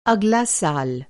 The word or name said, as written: अगला